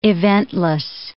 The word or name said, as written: eventless